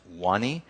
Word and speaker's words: Vanny: Then there is Wani, spelled V-A-N-N-Y, but it's pronounced Wani.